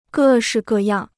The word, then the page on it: 各式各样: gè shì gè yàng